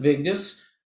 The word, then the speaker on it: Vigdis: So Mary Robinson asked President Vigdis of Iceland, and you did a good job of pronouncing her name.